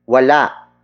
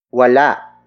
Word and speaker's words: wala: Word for word, it's, wala, si, Mario, sa, kanila. Wala, si, Mario, sa, kanila. Wala, si, Mario, sa, kanila. The first word is, wala. Wala. Wala. Wala is spelled as W-A-L-A.